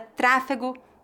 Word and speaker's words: tráfego: A palavra tráfego, com E e G, refere-se à movimentação ou fluxo de veículos. Note que a pronúncia dessa palavra é feita com a vogal E no meio.